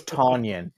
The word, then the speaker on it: Tonyan: It's Tonyan. It's T-O-N, not T-U-N.